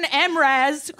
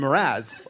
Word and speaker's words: Mraz: You know that it's Jason Mraz. – It's Jason Mr. Az is what I thought it was. – You've seen Mr. Az in conference 12 times.